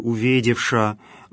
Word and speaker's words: уведевша: Причастие «уведевша», если вы будете читать этот текст по изданию, напечатанному церковнославянским шрифтом, то в этом слове «уведевша» вы на конце увидите букву «юс малый», которая в других случаях читается как «я» в середине или на конце слова. Но здесь сочетание «ша» плюс «юс малый» все равно читается как «ша».